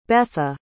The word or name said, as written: Betha